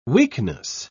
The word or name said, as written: weakness